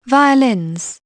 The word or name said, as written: violins